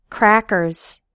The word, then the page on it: crackers: American English